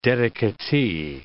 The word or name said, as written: dedicatee